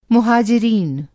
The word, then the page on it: मुहाजिरीन: مہاجرین